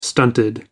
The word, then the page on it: stunted: American English